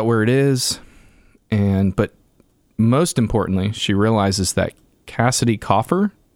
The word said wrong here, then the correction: Cressida: is that how we say her name? – Cressida. – Okay, Cressida.